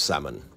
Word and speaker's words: salmon: I love salmon. And please note, I said salmon, not salmon. The L is silent.